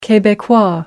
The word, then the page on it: quebecois: American English